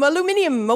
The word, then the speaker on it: aluminium: Something that in England is pronounced a little differently. – Hi-ho, aluminium, away.